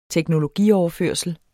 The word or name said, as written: teknologioverførsel